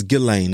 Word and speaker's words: Ghislaine: Man, they was talking about like Jelaine, or happy, you know, is her name Jelaine? – I think it's Ghislaine. That's how you pronounce it.